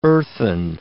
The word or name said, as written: earthen